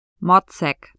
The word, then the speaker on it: Motzek: Motzek, das schreibt man M-O-T-Z-E-K.